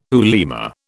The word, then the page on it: ulema: British English